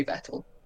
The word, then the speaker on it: Vettel: You say it like Martin Brundle used to say Vettel.